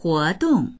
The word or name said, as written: huo2dong4